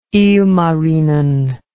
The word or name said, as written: ilmarinen